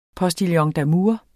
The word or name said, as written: postillon